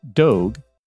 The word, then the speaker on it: Dogue: Dogue, which rhymes with Vogue, D-O-G-U-E, opened last month in the San Francisco's very trendy Mission District.